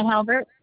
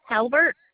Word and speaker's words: Halbert: Winona Halbert. – I'm sorry, can you repeat that? – Winona Halbert. – Can you spell that for me? I'm not finding an account. – Okay. Winona, W-I-N-O-N-A, last name Halbert, H-A-L-B, like boy, E-R-T.